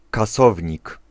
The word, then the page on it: kasownik: /kaˈsɔvɲik/